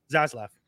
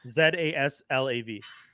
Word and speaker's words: Zaslav: Zaslav. Zaslav.